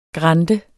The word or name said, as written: grande